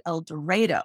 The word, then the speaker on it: El Dorado: The first thing your listeners need to know is that, even though it sounds strange, in El Dorado, they actually pronounce it El Dorado. It took me a long time to get used to that.